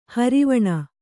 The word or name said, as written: ಹಾರಿವಾಣ